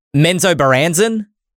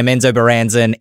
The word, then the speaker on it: Menzoberranzan: I'm going to challenge myself to pronounce this. I heard it pronounced like three times. Menzo Baranzen? – Yeah. – You done did it. That's how you pronounce it.